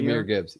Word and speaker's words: Jahmyr Gibbs: So, is it pronounced Jahmyr Gibbs? Is it Jahmyr? – Jahmyr Gibbs. Yep.